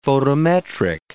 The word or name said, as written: photometric